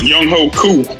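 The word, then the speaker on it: Younghoe Koo: K-O-O, and it's a Koo. – Yeah, it's, I wrote it on Twitter. – Young Ho Koo.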